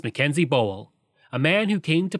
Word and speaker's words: Bowell: Also, I've heard his name pronounced Bowell and Bowell, but I believe Bowell is the correct pronunciation, and that's the one I'm going to use.